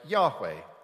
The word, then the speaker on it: Yahweh: We think it was probably pronounced something like Yahweh.